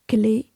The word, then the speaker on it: Clet: D'une part, le rapatriement, dans la chapelle de la rue du Bac, des reliques du bienheureux François-Régis Clé, ça s'écrit C-L-E-T, frère lazariste martyrisé en Chine en 1820, c'est-à-dire 12 ans auparavant.